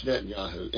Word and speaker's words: Netanyahu: Either it looks like Netanyahu. – Yeah, it's not Yahoo. It's pretty spelled pretty the way it sounds. – Yeah, anyway, but it is Netanyahu.